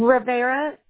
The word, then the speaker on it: Rivera: Rivera is spelled R-I-V. – Like river with an A at the end.